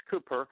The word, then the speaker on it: Cowper: This was written by William Cooper, and his last name is spelled C-O-W-P-E-R. It looks like Cowper, but it's pronounced Cooper.